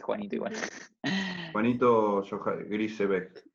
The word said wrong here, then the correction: Grisebach: Grisebach. – Grisebach.